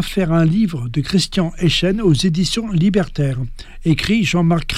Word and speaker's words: Eyschen: Nul doute que la publication du livre de Christian Eichen, ça s'écrit E-Y-S-C-H-E-N,